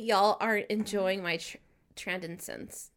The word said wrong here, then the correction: transcendence: You said it wrong. Transcendence. Transcendence.